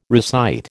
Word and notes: recite: American English

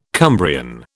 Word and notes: Cumbrian: British English

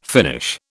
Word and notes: finish: British English

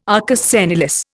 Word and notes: arcus senilis: Indian

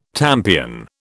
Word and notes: tampion: British English

